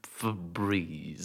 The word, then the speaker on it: Febreze: I thought it was just like Febreze. – It is spelled F-E-B-R-E-Z-E, so like Febrez. It is not Breeze whatsoever, and it never was Febreze.